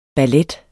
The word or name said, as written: BALLETTERS